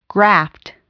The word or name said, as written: graft